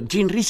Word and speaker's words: Jean Rhys: Jean Rhys se escribe Jean como Jean, digamos así, como el pantalón, y Rhys se escribe R-H-Y-S.